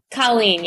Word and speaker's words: Colleen: Colleen, yeah, you got it.